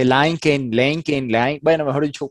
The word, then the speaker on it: LinkedIn: La correcta pronunciación es LinkedIn o LinkedIn con una T, porque son dos palabras en inglés. Link y luego la ED que viene pegada se pronuncia como una T después de la K en inglés. Entonces esa partecita se dice linked y luego lo pega uno con el in, entonces suena LinkedIn. Así se pronuncia originalmente en el idioma inglés.